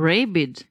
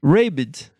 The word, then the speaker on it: Rabid: Como é que pronuncia isso? – Rabid. – Rabid, é.